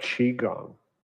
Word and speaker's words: Qigong: I would have pronounced that Qigong.